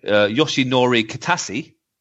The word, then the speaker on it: Yoshinori Kitase: Let me pronounce his name completely wrong here. Yeah. Yoshinori Kitase. – That sounds pretty good, actually.